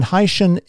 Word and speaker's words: hycean: You described it as a Haitian. And again, Haitian is a portmanteau word combining hydrogen and ocean, and that's spelled H-Y-C-E-A-N.